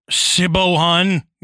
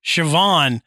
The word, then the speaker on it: Siobhan: And been like, oh, hi, Siobhan, you know, and then you go, oh, no, this is pronounced Siobhan.